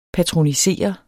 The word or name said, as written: patronisere